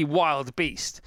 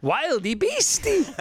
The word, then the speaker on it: wildebeest: and you want to say wildebeest. – Yeah, wildebeesties. – Yeah, because that's how it's spelled, isn't it? You know, it's spelled B-E-E-S-T. – Yeah. – They just had fun with that. It's clearly wild beast, but they've gone wildy beastie.